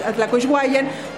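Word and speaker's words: Atlacuihuayan: que quiere decir Atlacuishguayan, que no pudieron pronunciar los españoles y por eso se dice Tacubaya pero el término correcto es Atlacuishwayan, lugar donde se recoge o donde abunda el agua.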